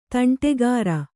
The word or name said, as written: ತಂಟೆಕೋರ